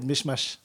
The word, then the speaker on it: Mechmache: vous n'avez pas écorché mon nom, mais là du coup je vais me permettre de vous reprendre. C'est Monsieur Mohamed Meshmash.